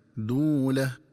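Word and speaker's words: دُولة: ينبغي التنبه للنطق الصحيح لكلمة دولة، فتقرأ بضم الدال مع واو مدية هكذا: دولة.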